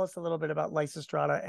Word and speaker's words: Lysistrata: And you use two examples, one with a name that I can't even pronounce, Lysistrata. Is that good? – Lysistrata. Yeah. – There you go.